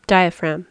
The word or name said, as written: diaphragm